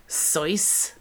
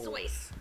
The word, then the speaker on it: Seuss: Have you guys heard that Dr. Seuss is actually pronounced like Sois? – No. – Sois.